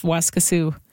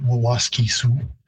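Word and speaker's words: Waskesiu: So the pronunciation isn't too far off. In Plains Cree, I'd say Waskasu, and in Woods Cree, which is the territory where it's located, Waskisu. What's happened there is simply the repeated syllable at the beginning has been lost. So instead of Waskisu, it's just Waskisu. The pronunciation in Cree again varies at the final from Siu, S-I-W as I would spell it, but you definitely get that I-W sort of merging to more of a Su sound.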